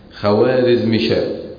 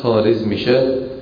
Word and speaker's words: خوارزمشاه: يقول لك الدولة الخوارزمية، خوارزمشاه، هي خارزمشاه والدولة الخارزمية، تكتب ولكنها لا تنطق.